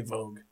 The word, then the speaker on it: Voogt: Voogt, which is definitely not right.